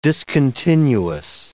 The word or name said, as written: discontinuous